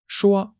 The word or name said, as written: shuo1